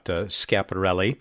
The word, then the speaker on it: Schiaparelli: A lot of people very curious, of course, about Schiaparelli. I'm trying to pronounce that with the proper Italian pronunciation of it.